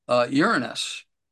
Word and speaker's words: Uranus: Uranus. And that's the correct way to pronounce it.